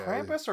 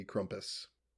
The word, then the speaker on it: Krampus: Uh, is it yeah, Krampus or Crumpus? – Uh, German pronunciation would be Crumpus.